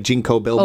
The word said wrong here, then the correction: Ginkgo biloba: Oh, like Ginkgo Biloba? – Ginkgo Biloba. Thank you.